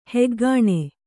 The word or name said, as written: ಹಗಣ